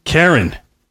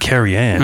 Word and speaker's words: Keryn: K E R Y N. – Why? What? – Feehan. – Oh, there you go. Karen. It's not Carrie Ann.